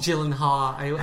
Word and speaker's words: Gyllenhaal: Gyllenhaal. I always mispronounce that. Gyllenhaal.